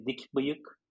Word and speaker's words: Dikbiyik: Well, the proper pronunciation of my last name is usually never mind in English, because it is so difficult to pronounce that. But in Turkish, we say Dikbiyik.